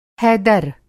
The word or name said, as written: हैदर